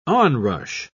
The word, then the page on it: onrush: British English